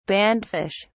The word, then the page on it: bandfish: British English